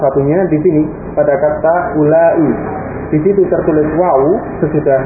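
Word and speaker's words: ulai (أولاء): Isim isyarah untuk jamak itu sama antara muzakkar dengan muannas, yakni lafaznya ulai. Ya, ulai. Ya, di situ tertulis ada wawu sesudah hamzah. Wawunya itu tidak diucapkan.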